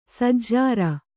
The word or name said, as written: ಸಜ್ಜರ